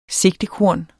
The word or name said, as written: sigtekorn